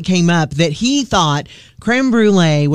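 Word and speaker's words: crème brûlée: It's a name, because it's pronounced in French, creme brulee.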